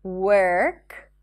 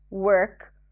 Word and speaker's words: work: Do not pronounce the O in words that have the W-O spelling, like work, work, worm, not warm, worth, and world.